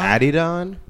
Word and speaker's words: Adidon: and then am I saying Adidon? Is that how you say it? Adidon? – Adidon.